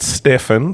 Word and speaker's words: Stefan: Stephen or Stefan, it's spelt Stefan. So go with Stefan.